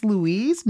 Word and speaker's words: Louise: I was like, no, actually it's Louise, not Lucille, you know.